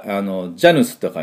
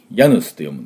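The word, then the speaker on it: Janus: だから、ジャヌスと書いて、ヤヌスと読むんですが、つまりそれが始まりって意味なんです。ですから、まあ、月の始まりなんで、それが、元々の意味になったと。まあ、ざっくり言うとね。そういう意味で、このヤヌスっていうのは、ラテン語ではもう入り口とか、そういう意味もありますので、これが月の始めっていう意味になりました。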